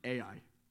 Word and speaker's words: Ai: It's spelled Ai, and it's pronounced Ai.